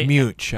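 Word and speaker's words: Muench: And it was really the photographs by people like Ansel Adams, Ray Manley, David and Joseph Munich, I hope I'm pronouncing that correctly. – Munich, I believe it is. – That what it is? Okay, David Munich is the correct pronunciation.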